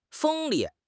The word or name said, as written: 锋利